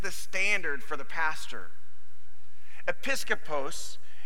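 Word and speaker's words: episkopos (ἐπίσκοπος): It's, I'm going to say it right. It's episkopos.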